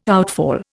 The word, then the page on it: outfall: Indian